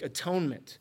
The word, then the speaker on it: atonement: He asks how he can make atonement. Atonement is sometimes pronounced at-one-ment to convey the end goal of an atonement action, right?